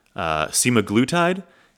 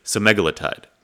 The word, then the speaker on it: semaglutide: This week, talking about Ozempic, and I'm going to be referring to it with its generic name, which is, and there's two different ways to pronounce it. I've heard it two different ways. Some people pronounce it semaglutide, and some people pronounce it semaglutide. I was introduced to it as semaglutide. That's how I'm going to pronounce it. I'm sorry if it drives you bananas.